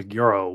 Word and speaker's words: GERO: So is that Giro, Giro, Hero, Euro? What? How do I say that? – I believe it's called a Giro wallet.